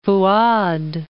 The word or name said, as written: Fu'ad I